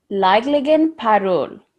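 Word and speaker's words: Ligligan Parul: It's according to this, it's pronounced Laidligon Parol. – Okay. – That's what their that's what the Giant Lantern Festival is. In so it's a hard G. – Yeah. – One silent G and then another hard G.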